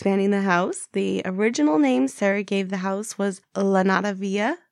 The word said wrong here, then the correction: Llanada: Yeah, Lanada, it's spelled L-L-A-N-A-D-A. And from what I could find, it sounds like Janata, mm-hmm.